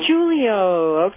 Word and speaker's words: DeJulio: It's actually, it looks exactly like you said it, but it's actually pronounced DeJulio. – DeJulio.